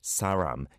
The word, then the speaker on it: 사람: Saram, yeah, the leal sound, especially for North Americans, it can be easy to say it like a ram, saramdo. But it's not an L, it's not an R, it's a leal.